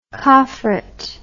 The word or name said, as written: coffret